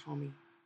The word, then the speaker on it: Homme: That would be Josh Homme.